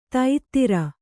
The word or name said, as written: ತೈತ್ತಿರ